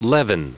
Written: leaven

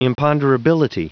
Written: imponderability

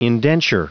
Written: indenture

